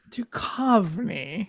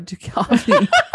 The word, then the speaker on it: Duchovny: Duchovny. – No, there we go. Duchovny.